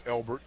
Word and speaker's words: Elbert: My birth given name is Elbert, E-L-B-E-R-T, and my grandfather named me, and my mom didn't care too much for that name.